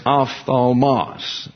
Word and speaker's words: ophthalmos: It's ophthalmos, last syllable emphasized. This is O-P-H-T-H-A-L-M-O-S.